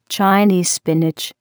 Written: Chinese spinach

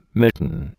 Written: mitten

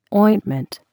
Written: ointment

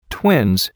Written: twins

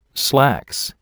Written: slacks

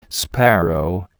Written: sparrow

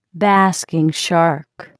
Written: basking shark

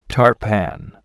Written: tart pan

